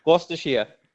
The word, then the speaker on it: Gloucestershire: Gloucestershire. – Yeah, how do you say it? – Gloucestershire.